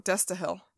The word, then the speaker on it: Destihl: So this week we are drinking, I think it's Destihl Brewery. It's D-E-S-T-I-H-L. – Destihl. – Oh, Destihl.